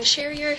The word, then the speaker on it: Cherrier: Susan spelled the regular way, S-U-S-A-N. – Okay. – Okay. – I say my name, I think. So Megan Sherrier, C-H-E-R-R-I-E-R.